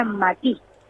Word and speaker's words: matí: Significa, se decía matí, con acento en la i, y significa recipiente.